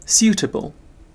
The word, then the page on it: suitable: British English